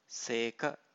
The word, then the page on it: seka: Pali